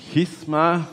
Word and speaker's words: Schisma: Schisma wird es eigentlich ausgesprochen.